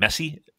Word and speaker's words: Messi: That's how you pronounce his name, Messi? Like M-E?